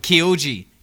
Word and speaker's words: Kyoji: Kyoji.